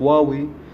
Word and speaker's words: هواوي: هاتف هواوي التي يقال أنها تنطق واوي